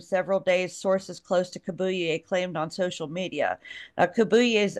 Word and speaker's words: Kabuye: His name's Stephen Kabuye.